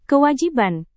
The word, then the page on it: kewajiban: indonesian